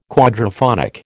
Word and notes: quadraphonic: American English

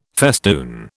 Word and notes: festoon: British English

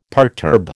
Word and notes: perturb: American English

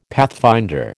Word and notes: pathfinder: American English

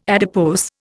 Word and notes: adipose: Indian

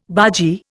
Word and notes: bargee: Indian

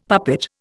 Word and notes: puppet: Indian

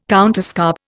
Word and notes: counterscarp: Indian

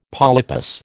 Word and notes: polypus: American English

